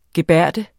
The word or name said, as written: gebærde